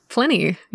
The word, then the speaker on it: Pliny: Pliny.